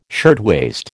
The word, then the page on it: shirtwaist: American English